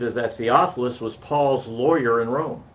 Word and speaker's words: Theophilus: In fact, he has a little article on who was who did Paul write Acts in, I can't pronounce his name, Theophilus. There you go, I got it. Theophilus.